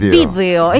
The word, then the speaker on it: vídeo: ¿Video o vídeo? – Video. – Es vídeo, es vídeo. Pero dice, pero francesa. – No, es vídeo, correctamente, claro.